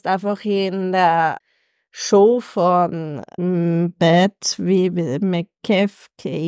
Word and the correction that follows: McAfee: ich kann das nicht aussprechen. – Beth McAfee? – Ja, genau, danke, dass du quasi irgendwie live bei seiner Talkshow dabei bist.